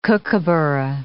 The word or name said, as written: kookaburra